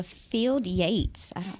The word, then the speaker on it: Field Yates: Yeah, uh, Field Yates.